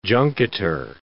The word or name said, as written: junketer